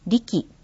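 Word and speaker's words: RE樹: リキはアルファベットの RE に樹木の樹と書いてリキと読みます。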